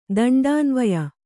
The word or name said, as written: ದಂಡಾನ್ವಯ